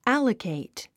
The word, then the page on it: allocate: ǽləkèit; アラケイト